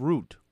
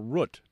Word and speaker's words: Root: Now let's go to the town of Root, or Root, depending on how you say it, where a town clerk there has denied marriage licenses for two men.